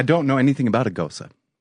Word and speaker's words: Gose: I'm like, can I have a goose? I'm like, oh, that's a Goza, sir. – Yeah, it's spelled G-O-S-E.